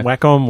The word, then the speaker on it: Wacom: A watch A Wacom. – Wacom. – That's we don't know how to say it either. – It's not a real word. We don't know how to say it either. – No. Wacom, Wacom.